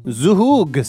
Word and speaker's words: zhoug: Z-H-O-U-G. How do you pronounce that? – Z-H-O-U-G, yeah. – Zug sauce.